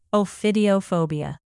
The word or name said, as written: ophidiophobia